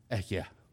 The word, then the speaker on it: Ehyeh: This is the Hebrew term, Ehyeh, is how it would be pronounced.